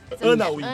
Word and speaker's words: Anna Wintour: É, tudo, na verdade, o Diabo é chamada Anne Wintour. Anna Wintour.